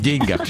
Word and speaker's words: деньгах: В общем, друзья мои, ударение на букву «Е» в деньгах считается устаревшей формой. Потому что употребление допустимо лишь в случае пословиц, поговорках, как, например, «не в деньгах счастье». Действительно, здесь можно сказать «не в деньгах». Во всех остальных случаях на второе слово. «Деньгах».